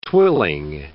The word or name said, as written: twilling